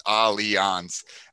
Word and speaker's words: Allianz: part of the biggest thing is we want people to pronounce our name right all the time. It's Allianz. And that's Allianz.